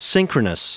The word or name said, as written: synchronous